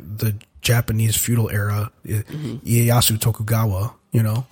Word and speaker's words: Ieyasu: It's I-E-Y-A-S-U. – I can't. – And the way that I've always heard that name pronounced is Ieyasu. And they were pronouncing it like Ieyasu.